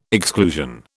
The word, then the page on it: exclusion: British English